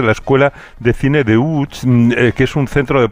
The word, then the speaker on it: Łódź: Aunque se pronuncia Łódź, se escribe Lodz, por si alguien lo busca Lodz, en Google. Casi no tiene nada que ver la pronunciación.